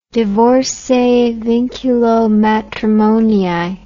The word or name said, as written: divorce a vinculo matrimonii